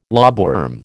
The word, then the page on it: lobworm: American English